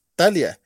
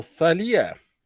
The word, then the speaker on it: Talia: Y es que como no lleva tilde, es Talia, no Talia o Zalía.